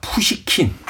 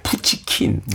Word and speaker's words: 푸시킨: 푸시킨. 예, 옛날엔 이 푸시킨 이름을 잘못 발음할 때가 많아요. 푸치킨, 뭐. 푸쇼킨, 푸쇼킨, 뭐, 뭐.